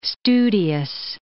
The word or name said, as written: studious